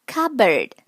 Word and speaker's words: cupboard: Cupboard。 cupboard，c u p，cup，p 在这里是不发音的，cupboard，cupboard，碗柜、碗橱的意思。